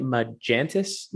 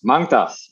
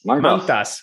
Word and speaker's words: Mangtas: And now you're founder at how do you say it? Magentis? – Mangtas. – Mangtas. – It's like mango. All right. And then tas.